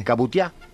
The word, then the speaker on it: cabutiá: Si no tenés el cabutiá. – Sí, el cabutia, ¿qué es? – El cabutia. – Claro. – Pero yo le digo cabutia porque yo lo digo con acento en la A.